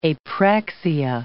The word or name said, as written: apraxia